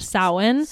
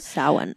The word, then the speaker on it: Samhain: You've probably heard or seen this word around somewhere, but it looks like Samhain. And it's pronounced Samhain. – Samhain.